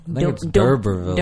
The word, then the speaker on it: D'Urbervilles: D'Urbervilles.